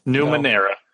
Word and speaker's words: Numenera: There are I know Numenera's. Or did I say it correctly? – No, you didn't. Numenera.